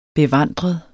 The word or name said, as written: bevandret